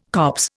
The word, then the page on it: copse: Indian